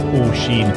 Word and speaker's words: Oisin: And it's actually pronounced Oisin.